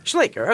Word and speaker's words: Schleicher: How do you pronounce that? – Schleicher, okay.